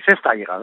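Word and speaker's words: festayres: Comment on prononce d'ailleurs festaires? – Oui, festaires. Oui.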